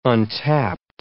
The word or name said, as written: untapped